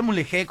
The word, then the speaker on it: Mulegé: Dice por acá, Gago, no se dice así. Se dice mulejé como si fuera jota.